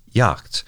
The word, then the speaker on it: Jagd: That German word, spelled J-A-G-D, is pronounced something like Yacht,